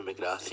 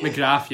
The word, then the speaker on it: McGrath: Lovely diving header, and it was a great ball into the box from McGrath. Is that how you say his name? – Jamie McGrath. Yeah. – McGrath, yeah.